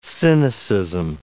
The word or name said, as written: cynicism